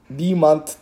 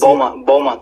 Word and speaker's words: Beaumont: Aslında Beaumont diye söylüyorlar ama o Fransızcadan gelme... Fransızcadan vermişler şehrin ismi. Ama buradaki yerler Beaumont diyor yani. Beaumont diyen falan hiç duymadım.